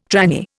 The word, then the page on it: tranny: Indian